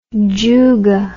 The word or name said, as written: juga